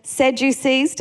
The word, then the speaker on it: Sadducees: There was the Sadducees.